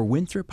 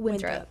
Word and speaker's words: Winthrop: Or Winthrop, how do I say that? – Winthrop, you're okay. – correct.